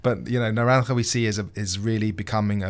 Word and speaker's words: Naranja: So the main area that we saw of growth in is literally south of Kendall, which is an area called Naranja. And we have seen... – Well pronounced. – Thank you. – For a guy with the British accent. You even got the "ch" in there.